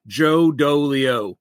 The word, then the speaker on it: Joe Dolio: Let me give you the best one first, though, is how to pronounce my last name, because everyone always wonders. My parents didn't like me very much, so they made sure that my name rhymed all the way through. Joe Dolio. It's just like it's spelled.